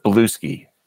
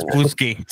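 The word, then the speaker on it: Bluesky: By the way, it's pronounced Blueski. – No. – No. – Oh, it's Blueski. Sorry. – Like Brewski.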